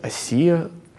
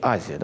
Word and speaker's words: Азия: что город Эфес – это столица провинции Асия, ну, такое славянское произношение, на самом деле мы можем говорить по-русски Азия, да, провинция.